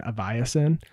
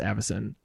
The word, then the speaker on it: Avacyn: I had a friend who used to pronounce Avacyn Aviacyn, and it was like, oh, that is such a fancy set, Aviacyn Restored. And then they were like, oh wait, it's pronounced Avacyn.